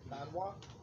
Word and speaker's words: Manhwa: M-A-N-H-W-A? Manhwa.